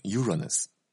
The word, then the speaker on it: Uranus: 乌拉诺斯这个词啊，英文发音叫 Uranus。